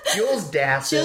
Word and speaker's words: Dassin: Jules Dassin.